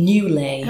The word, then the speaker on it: Newlay: Newlay. – Newlay? – Newlay. – How do you spell that? – N-E-W-L-A-Y.